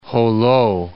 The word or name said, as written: Jolo